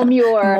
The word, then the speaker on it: Muir: Muir? – Oh, Muir.